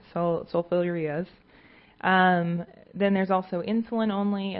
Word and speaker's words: sulfonylureas: Does anyone know how to pronounce this correctly? Sulfonylureas.